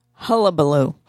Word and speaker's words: hullabaloo: If you live in the Los Angeles area, there has been a large hubbubaloo. I'm not even, how do you say that word? Hubbubaloo? – Hullabaloo.